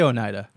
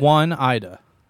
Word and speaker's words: Oneida: No, I say Oneida. – How would you say it? It's Oneida, right? – Yeah.